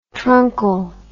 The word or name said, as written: truncal